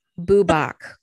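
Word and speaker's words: Bwbach: How the hell you say that? – Boobach. – Okay. – The Welsh words are particularly challenging. – Yeah, because this is spelled BWBA. So that's why I didn't get boo out of that.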